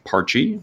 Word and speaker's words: Parchie: And the name, Parchy, which is spelled P-A-R-C-H-I-E, is named after her childhood imaginary friend, which is super cute.